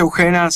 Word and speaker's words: Ugena: Estamos escuchando Paisajes de Lucrecia Ujena, ¿así se pronuncia? – Lucrecia Ujena, sí.